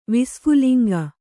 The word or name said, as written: ವಿಸ್ಫುಲಿಂಗ